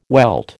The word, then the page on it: welt: American English